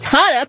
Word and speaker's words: Hannah: Hannah, pronounced like Benihana,